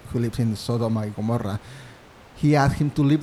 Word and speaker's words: Gomorrah: Going back to the Bible in the Old Testament, uh, when God decided to destroy um, Sodom and Gomorrah.